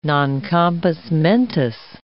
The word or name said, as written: non compos mentis